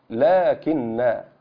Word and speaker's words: لكن: لكن. هذه الكلمة فيها حرف ينطق ولا يكتب، لا، هذه الألف التي نطقت بعد اللام،